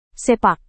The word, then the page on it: sepak: Indonesian